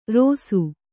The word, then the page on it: ರೋಸು: Kannada